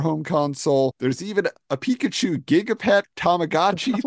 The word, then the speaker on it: Pikachu: Pronounced Pikachu.